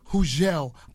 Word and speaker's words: Hugel: Am I saying that right? – Hugel. – Hugel. – Yes. – Hugel, the G's silent. – I love that. – Okay, Hugel.